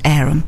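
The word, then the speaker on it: Averham: For anybody who's out there who's not a local, it's actually spelt Avaham, but it's pronounced Arum.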